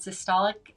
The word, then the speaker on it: systolic: What is normal blood pressure, and what's the difference between systolic and diastolic? – That's a really good question. – Okay. – No, you great. You said them great. – Did I say them right? Okay, good.